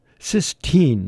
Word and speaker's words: Sistine: the cistern. Did I pronounce that correctly? And it was pretty Sistine. That's what it is, Sistine Chapel.